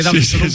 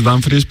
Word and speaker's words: Dumfries: Es correcto, no sabía que había debate de Dumfries. – Sí, porque aquí al principio decíamos Dumfries, pero estamos escuchando a los compañeros que dicen Dumfries.